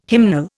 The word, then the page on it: hymnal: Indian